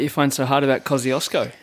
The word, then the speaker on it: Kosciuszko: Mount Kosciuszko.